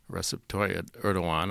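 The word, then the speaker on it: Recep Tayyip Erdoğan: The long-term prime minister and now president, the autocratic Erdogan, Recep Tayyip Erdogan, I think is a reasonably correct pronunciation, represents an Islamic party, which is technically unconstitutional under the established and very durable Ataturk constitution in Turkey.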